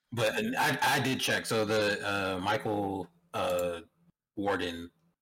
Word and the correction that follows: Waldron: is that his name? Did I say it right? – Waldron, sorry.